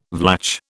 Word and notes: Vlach: British English

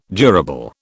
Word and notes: durable: British English

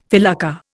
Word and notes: felucca: Indian

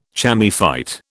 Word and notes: chamaephyte: British English

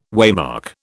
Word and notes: waymark: British English

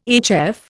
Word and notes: HF: Indian

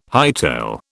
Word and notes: hightail: British English

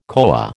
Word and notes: koa: American English

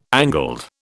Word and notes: angled: British English

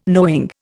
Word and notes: knowing: Indian

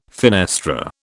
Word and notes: fenestra: British English

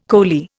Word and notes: coley: Indian

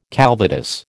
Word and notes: calvados: American English

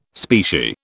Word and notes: specie: British English

